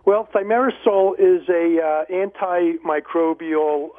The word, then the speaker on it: Thimerosal: Thimerosal. – Thimerosal.